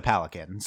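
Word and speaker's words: palanquin: Let's talk about the Palakin. So the Palakin. – Palakin, yeah. – Palakin. – Do you pronounce the N? – Whatever.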